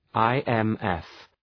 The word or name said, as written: IMF